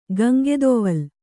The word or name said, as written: ಗಂಗೆದೊವಲ್